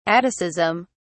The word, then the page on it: atticism: British English